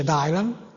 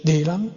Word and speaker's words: Dylan: Allora, perché ho questo Dylan Dog, che non si dice Dylan, si dice Dylan, come Bob Dylan?